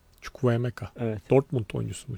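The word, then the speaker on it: Chukwuemeka: Danso, Vanner ve Çukwu Emeka. Dün nasıl okunuyordu o? O şekilde öyle.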